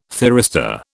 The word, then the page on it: thyristor: British English